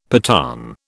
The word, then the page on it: Pathan: British English